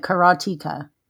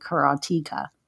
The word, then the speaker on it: Karateka: Because technically, if you go by the rules of phonics, and not everybody does, there's one consonant between the E and the A, which would make the E long, which would make it Karateka.